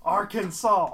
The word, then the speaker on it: Arkansas: How is this not Arkansas? How is it Arkansas? She's like, the English doesn't make any sense. – It's spelled the exact same way, God. Just arc. Arkansas.